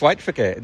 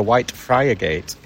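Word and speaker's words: Whitefriargate: When you're from Hull, it's White Frigate, isn't it? When you see it written down, then visitors come and pronounce it correctly. They say we're looking for White Friar Gate.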